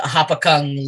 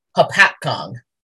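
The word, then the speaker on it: Hopatcong: possibly also aka Hoppy the Hopakung Lake Monster. I pronounced that wrong. I looked up how to say it. Hapakong.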